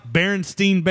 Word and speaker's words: Berenstain: It's spelled S-T-A-I-N. – Yeah, but it's pronounced Berenstain Bears.